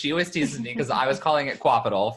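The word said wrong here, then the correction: Qapital: for the longest time. She's like, no, it's pronounced Capital.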